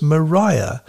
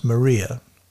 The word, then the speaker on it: Maria: The original pronunciation of the island name was Maria, though these days it's known as Maria.